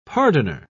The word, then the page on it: pardoner: British English